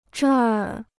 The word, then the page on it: 这儿: zhèr